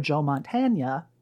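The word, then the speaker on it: Joe Mantegna: Concurrent to that happening, there is also Joe Montagna.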